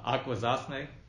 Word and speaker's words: Akwesasne: We're talking with Corey David, who grew up on the Akwesasne Reservation.